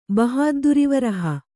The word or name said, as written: ಬಹಾದ್ದುರಿವರಹ